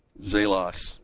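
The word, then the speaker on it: zelos (ζῆλος): Zelos is the word. It's a long E, zelos.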